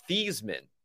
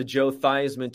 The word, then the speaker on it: Theismann: And the story is that Theismann's name before that was pronounced Theismann. but he changed it to Joe Theismann to rhyme with Heisman.